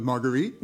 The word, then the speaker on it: Marguerite: Hint. Conveniently, Sois maudite rhymes with her name. – Well, does Sois maudite rhyme with Marguerite? – Yeah.